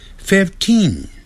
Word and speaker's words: fifteen: 15 呢，它是从 five 这个字过来，但是改成 fif，所以 fifteen，念成长的元音 fifteen。